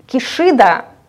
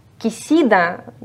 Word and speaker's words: Кисида: Поэтому Кишида, как бы, я не считаю это совсем корректным произносить. Дело не в российской транскрипции Поливанова. Кисида немножко ближе к правде, на мой вкус.